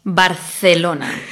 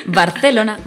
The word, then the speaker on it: Barcelona: Barcelona, Barcelona.